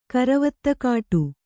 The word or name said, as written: ಕರವತ್ತುಕಾಟಿ